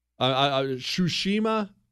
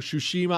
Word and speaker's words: Tsushima: Tsushima, I may be saying that wrong. Tsushima Island starts with a T, but Tsushima Island. Japan had a small defense force there.